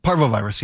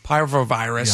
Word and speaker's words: parvovirus: Parvovirus? – Parvovirus, yeah. – Parvovirus.